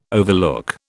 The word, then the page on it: overlook: British English